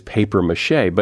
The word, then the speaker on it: papier-mâché: You talk about, this surprised me, when you see the word as it is pronounced in French, papier-mâché, you say the correct pronunciation is paper-mâché, but it seems like paper-mâché should be spelled paper-mâché if you want to pronounce it that way.